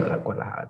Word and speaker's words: Guadalajara: The funny thing is, like this was on the way to Guadalajara, and on the way to Guadalajara, there was already like I think Benny was driving on the highway at night. – I just want to comment on your pronunciation there. That was delightful.